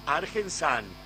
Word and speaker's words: Argensun: Y muchos dirán Argensan, y es real, Argensan. ¿Por qué Argensan? Es una mezcla de Argentina con Sunflower, que es como en inglés se pronuncia semilla de girasol con filtro.